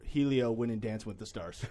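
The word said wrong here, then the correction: Hélio: and by the way, it's Elio. – Elio, sorry, Silent H.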